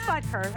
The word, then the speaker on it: Butker: It is Butker. I said it right.